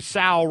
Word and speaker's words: Sowell: And I'm just glad to know that if things go haywire with Gary Gilliam or Jamarcus Webb, that Bradley Sowell rhymes with owl is our next option.